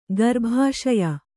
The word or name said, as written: ಗರ್ಭಾಶಯ